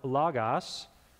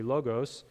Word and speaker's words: logos: This is the word logos, which would be the traditional pronunciation. The modern Greek pronunciation would be logos.